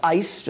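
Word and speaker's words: ice (εἰς): This little Greek word, E-I-S in English letters, it's pronounced ice, just like ice cube.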